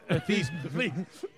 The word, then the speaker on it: Theismann: Pronounced Theismann? – Theismann.